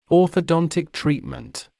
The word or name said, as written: orthodontic treatment